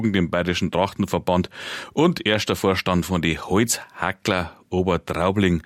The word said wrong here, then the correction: Holzhacker: Armin, habe ich es falsch gesagt? Holzhacker oder Holzhackler? – Holzhacker. – Holzhacker, gut, damit wir da nichts verkleinern, was eigentlich eine große Nummer ist.